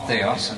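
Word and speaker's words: Theos (Θεός): in Greek, Theos, I'm using the modern Greek pronunciation, they say that it means invariably the Father.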